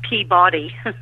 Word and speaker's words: Peabody: Peabody. – How did I get it? Did I get it right? – Well, we say it Peabody, but when people say it correctly, they say Peabody.